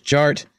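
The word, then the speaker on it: DJ Artt: DJ Art, with two T's for a double dose of that tink tink. The D is silent, so it's just Jart.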